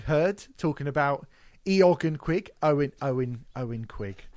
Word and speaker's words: Eoghan: So Eoghan is Owen, it's pronounced, but I'm going to call him Eoghan.